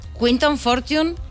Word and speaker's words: Quinton Fortune: Quinton Fortune, ¿lo hemos pronunciado bien? – Sí.